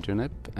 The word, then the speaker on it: Junip: So in Swedish, we have the month, June, which is pronounced juni. And in English, you have June. So it's very easy to just do Junip when you're talking Swedish and Junip when you're talking English.